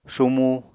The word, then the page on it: sumu: apatani